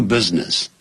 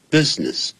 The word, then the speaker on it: business: business 不要念成 business business business 那个 S 或者那个 I 不发音 business